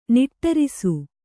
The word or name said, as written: ನಿಟ್ಟೇರಿಸು